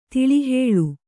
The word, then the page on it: ತಳಹೊಳ: taḷahoḷa